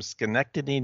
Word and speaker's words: Schenectady: So this first email is from Bob from Schenectady, New York. Say it right? – Schenectady. Yeah, Schenectady. And you said it right. It's Bob. – It's Bob. – B-O-B.